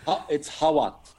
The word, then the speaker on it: Hawat: So, Wasim, pronouncing your last name, it's Hawat? Am I saying that right? Or I'm probably not. Oh, I am. Yeah. Okay. – It's Hawat. – Hawat. – H-A-W-A-T.